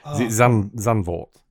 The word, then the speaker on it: Zandvoort: And also Vanderone, is it? – I was going to let you tackle the pronunciation. Zandvoort. – That's it. Zandvoort. Yeah.